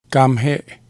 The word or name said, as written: Kamhe'e